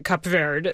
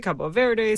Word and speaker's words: Cabo Verde: One, we just heard the man himself say Cap Verde, the right.